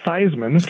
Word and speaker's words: Theismann: What I heard is that his name was Joe Theismann, and they changed his name to Theismann to rhyme with Heisman.